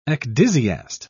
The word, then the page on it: ecdysiast: British English